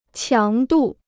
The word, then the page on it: 强度: qiáng dù